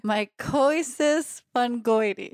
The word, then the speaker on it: mycosis fungoides: So you just taught me how to say mycosis fungoides.